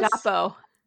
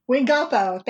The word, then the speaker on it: Wingapo: We ended up bumping into Pocahontas in her winter wear, so we decided to go see her and say, I'm going to say this wrong, Wingapo? Is that what she says? – Wingapo. – Wingapo, thank you.